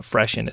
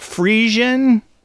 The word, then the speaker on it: Fressian: I'm going to call it Freshen. It's spelled Fressian, but it's a pun on Hessian. So it's not Frisian, it's Freshen.